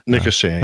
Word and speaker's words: Nicosia: Is that the way you pronounce that? Nicosia? – Nicosia, yes.